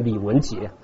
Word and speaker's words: 李文杰: 然后把这个我们啊，换你的名字，好，不要读错，不要读成李连杰，要读成李文杰。